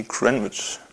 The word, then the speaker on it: Greenwich: die haben gemeint, das heißt nicht Greenwich, sondern irgendwie Greenwich. Ja.